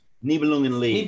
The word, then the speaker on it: Nibelungenlied: Nibelungenlied. – Nibelungenlied.